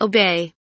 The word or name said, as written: obey